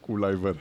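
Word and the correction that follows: Kluivert: Clivert. Sorry, Clivert, yeah.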